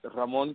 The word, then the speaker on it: Ramon: It's Ramon the right way is Ramon Castillon.